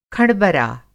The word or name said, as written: खँडबरा